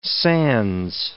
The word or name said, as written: sans